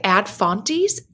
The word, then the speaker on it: Ad Fontes: There is a media chart at, I don't know how to pronounce the name of this website, but it's spelled A-D-F-O-A-D, yep. It looks like Ad Fontes, F-O-N. T-E-S.